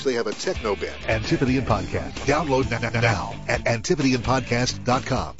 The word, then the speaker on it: Antipodean: That's how they pronounce this. Got to throw that tip in there. It's Antipodean.